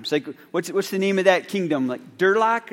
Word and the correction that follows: Duloc: or something? Duloc, yes, Duloc.